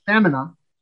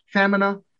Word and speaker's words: femina: If you make it F short E M I N A, pronounced femina, that means thighs. – Oh. – So femina, woman, femina, thighs.